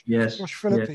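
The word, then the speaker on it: Philippe: But the interesting one who managed to edge into the top five was Josh Phillippe, I think you pronounce his name. Josh Phillippe. – Yes.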